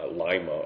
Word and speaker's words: Lima: I was just there, and autumn in Lima, Ohio, I'm told it's pronounced.